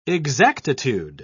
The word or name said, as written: exactitude